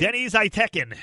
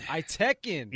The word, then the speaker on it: Deniz Aytekin: How do you say his last name? – Deniz Aytekin. – Aytekin.